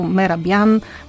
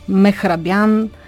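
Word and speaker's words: Mehrabian: И да кажем това правило пак, то е правено през 1967, извлечено като вследствие от експеримента, който е провел професор Мехрабиян. Той е от ермански происход, може да го срещнем с различни варианти, като Мерабиян, Мехрабиян и така нататък.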